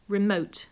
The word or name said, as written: remote